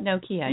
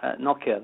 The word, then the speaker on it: Nokia: I then moved on to Motorola for a short time and then ended up at Nokia, depending on how you want to pronounce it. – To the US here, we call it Nokia, yeah.